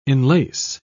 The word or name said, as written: enlace